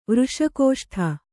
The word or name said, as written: ವೃಷಕೋಷ್ಠ